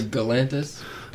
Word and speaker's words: Galantis: Well, I say Galantis – I say Galantis, but either way. – Hard G, soft G.